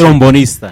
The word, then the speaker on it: trombonista: Hablando de pues un artista, gran artista, inspirador de muchísimos íconos de la salsa, inspirador de Willy Colón, que es un gran trombotista, ¿cómo se dice? – Trombonista. – Trombonista, sí, pues, trombonista.